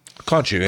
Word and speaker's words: Khonshu: One section of the article, translated into English, describes the powers that, I never pronounce this right, Khonshu? – Khonshu, yeah.